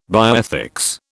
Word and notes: bioethics: British English

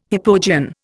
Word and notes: hypogean: Indian

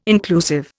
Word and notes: inclusive: Indian

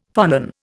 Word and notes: phonon: Indian

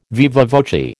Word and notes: viva voce: American English

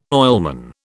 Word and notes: oilman: British English

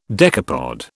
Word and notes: decapod: British English

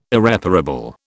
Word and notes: Irreparable: British English

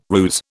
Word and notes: ruse: British English

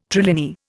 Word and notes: trilinear: Indian

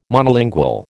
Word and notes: Monolingual: American English

